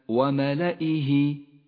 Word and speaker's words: وملائه: تأمل كيف رسمت كلمة وملائه وملائه، لاحظ هذه الياء التي لونت بالرمادي، لونت بالرمادي لأنها لا تلفظ وهي من زيادات الرسم العثماني.